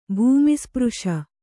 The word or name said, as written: ಭೂಮಿಸ್ಪøಶ